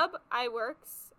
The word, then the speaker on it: Ub Iwerks: Ub Iwerks, That's how you pronounce his name.